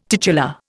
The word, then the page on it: titular: Indian